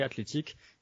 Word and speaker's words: Athletic: nom anglais Athletic, et club avec euh, la prononciation espagnole du U, donc euh, club et pas, et pas club.